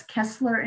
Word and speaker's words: Keslar: It's Keslar Insurance, but we spell our name the hard way. So it's K E S, like like uh, the S as in Susan, L A R, because most people will spell it with two S's.